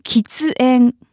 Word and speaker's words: 喫煙: On the flip side,Smoking is 喫煙, 喫煙.